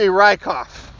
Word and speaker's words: Richoff: Because to me, there is a lot more to my last name than 9 letters that are now pronounced very un-German. It should be Rykoff.